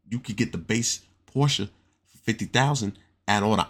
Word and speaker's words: Porsche: It's Porsche, not Porsche, you know, and it's Audi, not Audi.